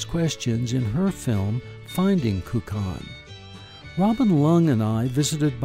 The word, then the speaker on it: Kukan: So the word kukan is really a Chinese word that in Mandarin is more, pronounced more like gugan, and it's made up of two different Chinese characters.